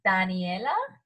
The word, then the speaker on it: Daniela: And I'm just like, my name is like Spanish, you know, so it's Daniela.